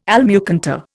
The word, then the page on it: almucantar: Indian